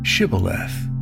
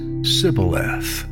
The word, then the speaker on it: Shibboleth: They asked everyone to say, "Shibboleth." Their perceived enemies, the Ephraimites, who were really fellow Israelites from a different tribe, would pronounce this Sibboleth.